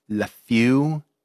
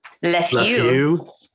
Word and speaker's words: Lafew: Lafew. Come on. You guys got to say it. – Lafew.